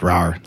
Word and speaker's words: Rar: We are drinking beers from Rar Brewing, pronounced Rar like a cat, out of Cambridge, Maryland.